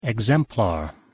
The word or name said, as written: exemplar